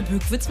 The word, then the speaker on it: Bückwitz: Genauso wie Segeletz mit langem E oder Bückwitz mit langem Ü.